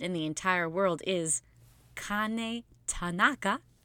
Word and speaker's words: Kane Tanaka: Said it right. – You said it more right than usual. We do a lot of practice episodes of this podcast, and that's the most right. It's Kane Tanaka. You got scared in the middle. – I panicked. – She kept saying Tanaka, and I was like, it's not a Jewish festival of lights. It's a Japanese woman.